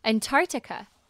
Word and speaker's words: Antarctica: 它的片段呢是把它，我们要把它切开来看的话是 A-N-T-A-R-C-T-I-C-A Antarctica Antarctica Antarctica。那这边中间的第一个 C 呢其实是短，非常非常短的气音，然后微微带过去就好，不用太加重在这个字的发音 Antarctica Antarctica。